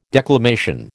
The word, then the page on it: declamation: American English